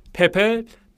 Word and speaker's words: پپه: با تلفظ درستش پپ پپه پیام آور صلح و آرامشه آره.